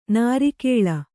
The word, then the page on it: ನಾರಿಕೇಲಿ: kannada